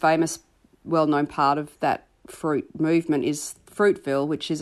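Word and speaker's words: Froute: So Fruit, which is spelt F-R-O-U-T-E, F-Root, pronounced Fruit, began in East Gippsland a few years ago as a project which was producing art related to fruit.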